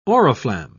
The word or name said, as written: oriflamme